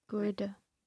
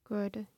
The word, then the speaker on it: Göhrde: Göhrde. – Göhrde? – Göhrde, yeah.